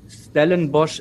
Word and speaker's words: Stellenbosch: Ja, genau, das Abenteuer beim Stellenbosch FC.